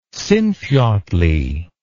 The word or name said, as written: Sinfjotli